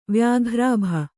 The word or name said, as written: ವ್ಯಾಘ್ರಾಭ